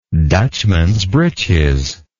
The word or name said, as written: Dutchman's breeches